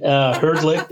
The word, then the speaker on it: Hrdlick: Have I pronounced your name correctly, by the way? – Yes, you did. Hrdlick is perfect.